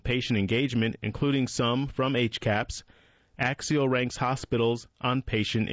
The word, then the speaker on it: HCAHPS: The survey is called the Hospital Care Quality Information from the Consumer Perspective, but goes by the acronym HCAHPS, colloquially pronounced HCAPS, and hospitals are taking this survey seriously.